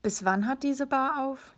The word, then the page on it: aufhaben: German